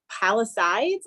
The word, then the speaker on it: Palisade: Wait, but first let's figure out, how do we pronounce it? Is it Palisades, like Bryan said, or Palisades? – Palisade. – That's what I would think.